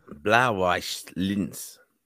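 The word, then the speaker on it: Blau-Weiß Linz: Blauweiss Linz.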